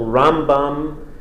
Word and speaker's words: Rambam: And you should distinguish the pronunciation. Usually we accent the first syllable, Rambam, and then in the Ramban, it's the second syllable, Ramban.